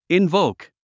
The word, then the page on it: invoke: invóuk; インボーク